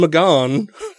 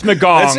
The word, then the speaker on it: McGahn: Acting Attorney General Sally Yates tells White House counsel Don McGahn. – Now you can't pronounce names. It's McGahn.